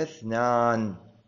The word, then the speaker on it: اثنان: طبعا انتفى اثنان، فائدة إملائية، لا تكتب اثنان بالهمزة ولا تلفظ اثنان، تقول انتفى اثنان، رأيت اثنين، مررت باثنين، لا تقول رأيت اثنين ومررت باثنين، وهذا من الأخطاء الشائعة.